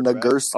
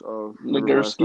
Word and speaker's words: Nagurski: Nagurski. – Oh, it was against Steve Brooks. – Nagurski.